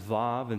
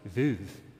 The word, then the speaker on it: Veuve: I thought it was Veuve Clicquot, but online it says Vav, and somebody afterwards came up and says, "No, it's Veuve."